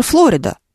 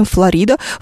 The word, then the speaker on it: Флорида: Мы не говорим «Лондон», мы говорим «Лондон», мы не говорим даже «Флорида», потому что мы образованные люди, мы говорим «Флорида». В русском языке ударение почему-то в слове «Флорида» переползло на букву «и», и мы произносим его именно так, как в словаре у нас написано.